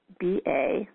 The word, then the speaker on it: Merkabah: But it's basically, and you can look up the word Merkaba, and it's Merkaba, sometimes spelled with an H at the end. That's Merkabah.